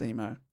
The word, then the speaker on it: Imo: Spelled IMO, but I have heard it pronounced Emo.